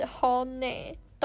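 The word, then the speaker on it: HONNE: 所以一开始的 James 和 Andy 他们以为 Hone 就是念 Hong，殊不知日语发音是 Hone。懂日文的人应该就知道 “hone” 的意思就是 “true feeling” 真心话的意思吧？那他们觉得 “hone” 听起来比较像是英语的发音，因此就将错就错，这样错下去了。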